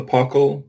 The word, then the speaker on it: paucal: the next option you can have is apocal, which is a few of something. – Sometimes when people talk about the paucal, which is spelled P-A-U-C-A-L, by the way, there's an attempt to assign an exact number range to that, mm-hmm.